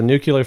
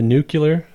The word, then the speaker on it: nuclear: The nuclear. – Oh. – How do you say it? – Read it. Look at the letters. – Nuclear. – Yeah. Nah, you said that. Like you say like George Bush.